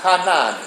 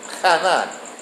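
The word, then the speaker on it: chanan: It is spelled in the Hebrew, Hanan, C-H-A-N-A-N. C-H-A-N-A-N, Hanan.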